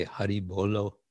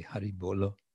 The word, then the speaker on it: Hari Bolo: We say Hari Bolo, or Hari Bol for short, but it's actually Hari Bolo, which means chant.